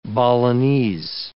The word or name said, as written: Balinese